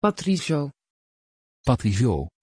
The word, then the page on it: patrizio: Dutch